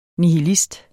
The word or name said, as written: nihilist